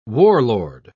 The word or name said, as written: warlord